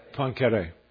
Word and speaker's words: Poincaré: Another, I think, physicist, Poincaré, am I pronouncing his name properly for those who know? Pardon? Poincaré, thank you.